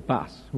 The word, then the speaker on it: Buss: David Buss, I presume that's how he pronounced his name, it's B-U-S-S. I mean, you really wouldn't want to be called Buss, would you? So we'll call him Buss.